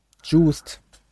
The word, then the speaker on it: Juiced: Wie wie spricht man das aus? Juiced. Joyce? – Juice? – Juiced. Juiced. Genau.